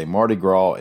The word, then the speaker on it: Mardi Gras: Mayor Stimpson also knows the correct Alabama way to say Mardi Gras is that it rhymes with Tim McGraw.